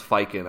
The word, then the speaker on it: Ficken: And I think that they had quite a bit of concern about the hamstring, and that's why Ficken was probably getting, or actually, I heard it pronounced Fiken on the broadcast last night, but Ficken, whatever you want to call him, we'll call him Sam.